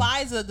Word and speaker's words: Pfizer: The Pfizer, the P is silent.